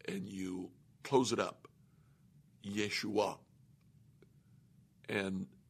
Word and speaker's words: Yeshua: The Hebrew pronunciation of the name Joshua is Yeshua. Now, if you'll notice there at the end, it's a sound that we don't make in English. It's called a glottal stop.